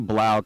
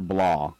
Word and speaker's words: Blau: I say blau because I'm so German. B-A-L-U. How do you say it? Do you say blau or blah? – Yeah. No, blau. Blau. – Blau. – Right. – Okay. B-L-A-U.